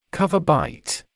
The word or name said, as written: cover bite